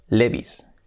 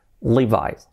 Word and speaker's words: Levi's: La penúltima palabra, la penúltima marca es la súper conocida de pantalones vaqueros Levi's. Levi's, que hemos dicho aquí, quiero unos Levi's. Y la pronunciación no se pronuncia Levi's, se pronuncia Levi's. Levi's. Así, fijaos, Levi's. Nada de Levi's, Levi's.